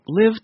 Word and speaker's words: LIV: That would be the Saudi Arabia-backed LIV Tour. That's L-I-V, pronounced as we said, LIV, like live large, which the tour did, offering eye-popping paychecks to attract players from the PGA.